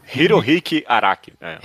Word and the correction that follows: Hirohiko: O que, que eu falei? Ah, Hiki. Ah, tá. Hirohiko.